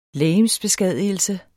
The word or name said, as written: legemsbeskadigelse